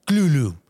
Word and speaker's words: Cthulhu: Ah, mira, según S.T. Yoshi, académico y biógrafo de Lovecraft, se pronuncia Clulu, con la K bastante gutural.